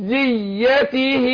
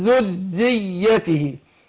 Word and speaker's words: ذريته: شوف يا نور، اسمعيني انت عندك ومن ذريته، الراء مشددة مكسورة وبعدها الياء مشددة مفتوحة. انا عايز لازم عندي هنا لازم يفرق نطق الحرف المشدد عن مثيله الغير مشدد. فانا عندي اقول ومن ذريته داود ومن ذريته، واخد بالك؟ ابان تشديد الراء والياء.